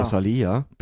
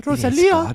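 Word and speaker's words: Rosalía: Rosalia. Bitte Betonung. – Okay. Entschuldigung, ich weiß nicht, wie man es ausspricht. – Rosalia.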